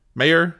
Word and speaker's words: Meijer: So Peter Meyer, I think, yeah, former representative Peter, I guess it's Meyer. How do you pronounce this? M-E-I-J-E-R. Meyer? – Mayor. – Mayor. Yeah, I'd say mayor or mayor.